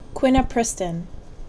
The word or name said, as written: Quinupristin